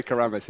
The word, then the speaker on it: Keremas: I'm pretty sure they say Keremas in Bali. Keremas. They like the short A's.